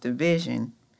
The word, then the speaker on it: DVSN: And what's crazy is I finally found out that DVSN is pronounced Division.